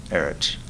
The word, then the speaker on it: Erich: My name is Eric England. Last name is spelled E-N-G-L-A-N-D. – And first name? – Eric, E-R-I-C-H.